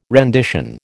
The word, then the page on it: rendition: American English